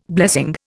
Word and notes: blessing: Indian

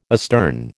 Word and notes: astern: American English

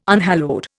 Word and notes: unhallowed: Indian

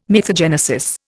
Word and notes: mythogenesis: Indian

